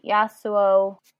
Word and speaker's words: Yasuo: And then Yasuo, did I say that right? – Yeah.